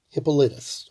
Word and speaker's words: Hippolytus: H-I-P-P-O-L-Y-T-U-S. Hippolytus, or Hippolytus, depending, I guess, on the pronunciation of that one.